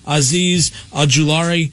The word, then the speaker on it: Azeez Ojulari: And then outside linebacker, defensive end out of Georgia, Azeez Ojulari to the Kansas City Chiefs.